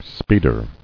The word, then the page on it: Speeder: speed·er